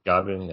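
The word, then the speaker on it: Gavi: I thought, I think I pronounced it Javi or Gabi. He was incredible for Barcelona. – I think it's Gabi, yeah. – Gabi, yeah.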